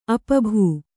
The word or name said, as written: ಅಪಭೂ